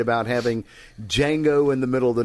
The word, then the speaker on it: Django: Is it Django or Django? – The D is silent. – Okay, so Django Unchained from 2012.